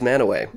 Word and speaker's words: Mantua: I got lots of corrections on my pronunciation of Mantua. It is Manaway.